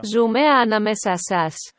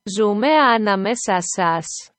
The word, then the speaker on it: Ζούμε ανάμεσά σας: This is the Greek pronunciation. There we go. – Ζούμε ανάμεσά σας. – We live between you. – Ζούμε ανάμεσά σας.